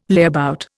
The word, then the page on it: layabout: Indian